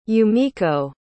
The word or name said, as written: yumiko